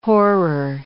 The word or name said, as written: horror